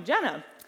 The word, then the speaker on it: Jenna: And when I would tell kids and people my name, I usually would get a little bit of like a, Jenna? Jenna. And I just figured, hard J's, man, can be really tough to say.